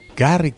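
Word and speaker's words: Garry: Gary, se dirá, ¿no? – Mm. – Ustedes que saben los nombres de los deportistas. Gary sí. Kasparov. – Gary, ¿cómo se dirá? Sí. Gary Kasparov.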